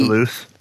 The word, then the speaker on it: Toulouse: In France, in a specific city in France, Toulouse, with my horrible pronunciation, but it's the Toulouse.